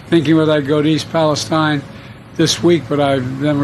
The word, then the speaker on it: East Palestine: The reason why Joe Biden doesn't know that it's East Palestine